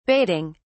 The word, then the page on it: baiting: British English